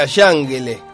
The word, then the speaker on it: Cángele: Si fuera pronunciado en turco sería Yangele, porque la C se pronuncia como Yaye, Yiyo, Yo. Le dicen, la C la pronuncian bien, pero dicen Kangele, le dicen.